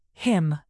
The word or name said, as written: him